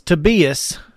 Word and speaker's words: Tobias: UAB has a player on their team who's from Norway, and his name is spelled Tobias, but it is Tobias. So make sure it's Tobias.